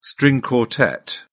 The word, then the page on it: string quartet: British English